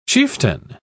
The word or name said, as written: chieftain